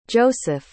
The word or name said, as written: joeseph